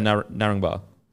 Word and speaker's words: Narangba: really Narangba.